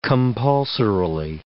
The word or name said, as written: compulsorily